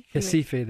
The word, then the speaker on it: Recife: In Recife? – Recife, they say, yeah.